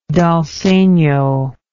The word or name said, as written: dal segno